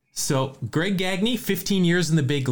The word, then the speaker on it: Gagne: All right, so you're up to three even, and this is a guy, I always say his name wrong because there was a Dodger pitcher recently that has the same spelling but a different pronunciation. This is Greg Gagne.